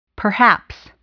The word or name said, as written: perhaps